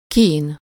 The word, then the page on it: tourment: France; /tuʁ.mɑ̃/